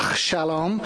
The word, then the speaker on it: Shalom: I'm trying to get my Hebrew pronunciation correct. It's Shalom. The beginning bit isn't the best, but I'm learning.